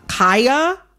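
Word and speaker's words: Khia: I am going to, in this conversation, pronounce Kaya, the rapper, with the long I.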